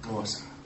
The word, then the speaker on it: Xhosa: But the two big languages over there besides English is Afrikaans, which is like a Dutch, or Xhosa, which is actually pronounced Xhosa.